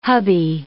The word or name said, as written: hubby